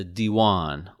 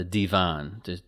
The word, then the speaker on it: Diwan: Okay, so along with this office of the Sultan is the Diwan, or the Divan, depending on which pronunciation you're using of this Arabic or Turkish.